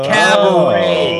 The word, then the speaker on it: Cabaret: Cabaret. – Oh. Cabaret. Oh, with a silent T.